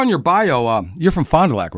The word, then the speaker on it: Fond du Lac: It's pronounced Fond du Lac. – Fond du Lac. – And he's like, no, it's Fond du Lac.